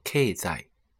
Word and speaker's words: 経済: 那引申一下，在日本呢，经济有两种说法，一种是片假名的 economy，还有一种就是我们汉字的经济，读作 k 在 k 在。